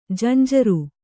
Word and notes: ಜಂಝಾರ: janjhāra

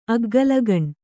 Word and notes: ಅಗ್ಗಲಗಣ್ಣ: aggalagaṇṇa

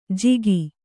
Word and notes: ಜಿಗಿ: jigi

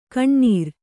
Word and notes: ಕಣ್ಣುರಿ: Kannada

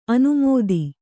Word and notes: ಅನುಮೋದಿ: anumōdi

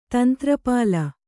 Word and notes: ತಂತ್ರಪಾಲ: kannada

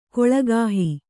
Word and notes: ಕುಲಗೇಹ: kulagēha

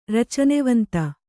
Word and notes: ರಚನೆವಂತ: kannada

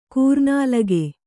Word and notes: ಕೂರ್ನಾಲಗೆ: kannada

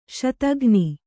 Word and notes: ಶೋಥಘ್ನಿ: śothaghni

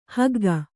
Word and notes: ಹೊಗ್ಗು: kannada

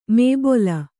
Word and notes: ಮೇಬೊಲ: Kannada; mēbola

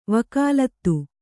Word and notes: ವಕಾಲತ್: vakālat

